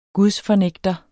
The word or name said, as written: gudsfornægter